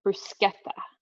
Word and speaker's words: bruschetta: Bruschetta. That's how you're supposed to say it, but I don't know.